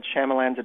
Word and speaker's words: Shyamalan: Well, let me offer you a mnemonic to remember how to pronounce his name. I always think in my head, Shyamalan's a ding-dong.